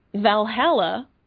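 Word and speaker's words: VA-11 Hall-A: It is called Valhalla, and it is spelled V-A-11-H-A-L-L-A.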